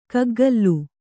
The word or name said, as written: ಗುಗ್ಗುಲು